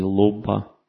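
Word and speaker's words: lobha: Следующий враг человека называется лобха. Или лобба. Там иногда ха не произносится в санскрите. То есть там с придыхательный звук ха.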